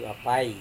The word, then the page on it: apai: Serewen